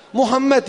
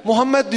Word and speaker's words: Muhammed: Normalde mim fethayla yani üstün değil. Damme ile yani ötre. Muhammed yani anlayacağınız. Bu böyle okunur. Mim, ha, mim, del, Muhammed diye okunur Arapçada.